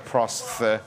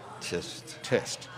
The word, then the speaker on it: prosthetist: A prosthetic is a prosthetist. – Tist. – That's right. – Yeah. Not prosthesis.